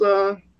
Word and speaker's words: Arif: You know, and Arif, is that how you pronounce his? – Arif, thank you.